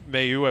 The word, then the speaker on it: Mailloux: Selecting Logan Mailloux. I think I'm pronouncing that correctly. – Mailloux. – I've heard it five different ways.